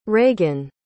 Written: raegan

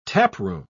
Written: taproom

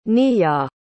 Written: niah

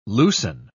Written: loosen

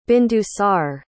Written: bindusar

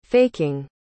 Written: faking

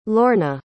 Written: Lorna

